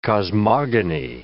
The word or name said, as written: cosmogony